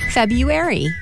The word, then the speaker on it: February: You need to say the R. So instead of the middle being U-ary, it's supposed to be Rue-ary. February. – February. Jeez.